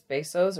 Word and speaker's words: Bezos: It is Bezos or Bezos. The Spanish pronunciation is Bezos. But the American pronunciation, which is a mangled version of the Spanish, is Bezos. So I hear a lot of people saying Bezos and Bezos. None of those are correct. It's Bezos. So anyway, so my thoughts on that is that marketing is difficult.